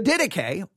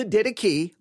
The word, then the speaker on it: Didache: The Didache, or the Didache, literally, I can give you two books that say, pronounce it Didache, and another book will say, pronounce it Didache.